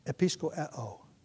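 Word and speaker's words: episkopeō: Episcopa. Episcopo. I left the syllable out. Which is translated, see to it. And I used the, I mangled the pronunciation of the Greek verb because its noun form is more familiar.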